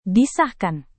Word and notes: disahkan: Indonesian

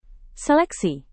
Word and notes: seleksi: Indonesian

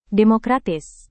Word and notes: demokratis: Indonesian

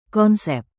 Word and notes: konsep: Indonesian

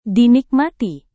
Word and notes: dinikmati: Indonesian

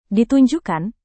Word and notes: ditunjukkan: Indonesian

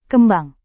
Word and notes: kembang: indonesian

